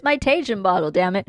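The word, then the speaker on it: Tajín: Tajin It's pronounced Tajin, or in English, Tajin.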